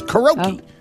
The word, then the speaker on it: karaoke: And I don't know if people know, but there is no I in the word karaoke. We actually, the whole world pronounces that word wrong. It's karaoke.